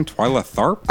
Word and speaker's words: Twyla Tharp: Yeah, David Byrne moved on to a collaboration with choreographer, how do you say this woman's name? Twyla Tharp? – I think you nailed that.